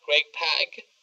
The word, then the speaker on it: Greg Pak: Yes, so that's a book we recommend, and there's then another book that is being done by Greg Pak.